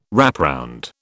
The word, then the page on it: wrapround: British English